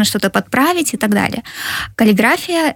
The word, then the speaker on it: каллиграфия: А вообще, как правильно все-таки? Вообще, по нормам русского языка, каллиграфия. Но в среде дизайнеров, насколько я знаю, частенько употребляется каллиграфия почему-то. – Здесь ударение не, я думаю, не столь важно.